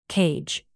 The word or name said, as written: cage